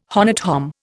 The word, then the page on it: honnête homme: Indian